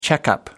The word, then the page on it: checkup: British English